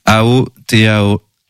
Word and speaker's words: Aotearoa: Et je le prononcerai qu'une fois, parce que je prononce pas le maori, désolé, mais la Nouvelle-Zélande, en réalité, ça s'appelle Aotearoa.